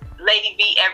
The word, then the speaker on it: Ladi Vee: My name is Lady V everywhere.